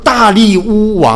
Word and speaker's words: 大利乌: 你如果读很多历史上的一些小说啦，或者是看那些好莱坞的电影呢，他都可能把它翻译成大流士，因为他的那个变成英文的拼音呢是 D-A-R-I-U-S，变成大流士，其实是大利乌啊，应该是用这样子一个发音来的。那么大利乌王啊，立但以理呢是做总长的，真正是一人之下，这个千万人之上的。